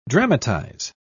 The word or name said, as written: dramatize